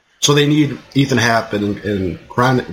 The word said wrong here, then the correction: Koenig: what is this? How do you pronounce his last name? – Koenig, to be the dudes.